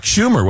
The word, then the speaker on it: Schumer: I believe that was Chuck Schumer, was it not? – Yes. Yes. – Yeah. – Senator's name rhymes with rumor.